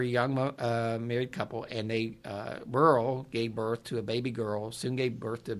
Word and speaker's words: Beryl: In 1948, a young Welshman named Timothy Evans and his wife, Burl, moved. – Burl? – B-E-R-Y-L. How would you say that? – Burl, okay. – You said it right.